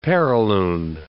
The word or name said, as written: perilune